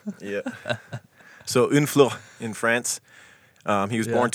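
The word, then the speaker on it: Honfleur: I had to look up the pronunciation because it looks like Honflor to me. – Unflor. – Obviously, it'd be an American, but it's Unflor.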